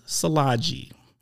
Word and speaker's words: Szilagyi: So without further ado, this week's guest is Liz Szilagyi.